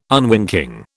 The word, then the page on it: unwinking: British English